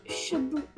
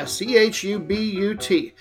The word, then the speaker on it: Chubut: Now, there is an area in Argentina, a province, and I don't know how to pronounce it. Do you? – Chubut. – Yeah, Chubut.